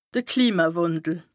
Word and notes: Klimawàndel: Bas Rhin

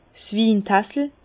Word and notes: 's Wintassel: Bas-Rhin; Herrlisheim

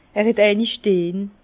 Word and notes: er het eini steh: Bas-Rhin; Herrlisheim

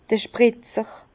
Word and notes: d'r Spritzer: Bas-Rhin; Herrlisheim

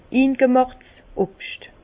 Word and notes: ingemàchts Obscht: Bas Rhin; Herrlisheim

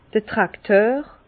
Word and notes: d'r Trakteur: Bas-Rhin; Herrlisheim

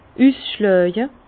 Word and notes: üsschlàje: Bas-Rhin; Herrlisheim

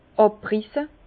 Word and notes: àbrisse: Bas-Rhin; Herrlisheim

